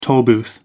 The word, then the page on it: tollbooth: American English